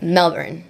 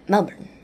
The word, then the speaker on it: Melbourne: Australians, the tip is you've got to say it as lazily as possible. And that's probably how Australians say it. – Melbourne. – Yeah. – Melbourne.